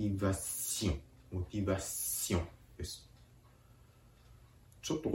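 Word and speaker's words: motivation: ポイントとしては、んを発音する寸前で鼻から息を抜くように、むしろ鼻からんと発音するように発音するところがポイントです。モティバシオン、モティバシオン。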